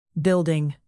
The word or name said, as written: building